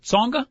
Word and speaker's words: Tsonga: On the men's side, Roger Federer will be taking on your guy, Brian, Joe Wilfred Tsonga. The T is silent.